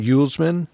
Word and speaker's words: Uelsman: Uelsman. – Mm-hmm. – Uelsman.